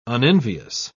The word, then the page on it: unenvious: British English